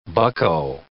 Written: bucko

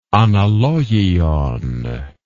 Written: analogion

